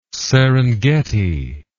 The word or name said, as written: serengeti